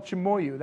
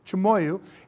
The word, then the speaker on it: Chimoio: So the city is called Chimoyu. That's how you pronounce that. Okay? Chimoyu.